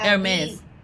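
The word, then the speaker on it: Hermès: And there was also talking the H is silent. – Was also saying how Hermes.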